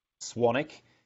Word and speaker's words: Swanwick: My last name is pronounced Swanick, but it's actually spelled Swanwick.